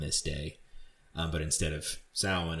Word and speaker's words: Samhain: it looks like it's pronounced Samhain, but I believe it's pronounced Samhain or Samhain, which was a pagan holiday that took place on November 1st.